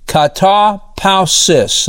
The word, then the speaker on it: katapausis: Now, I want you to notice this word rest is the Greek word katapausos. Katapausis. That's how you go. That's how you pronounce it.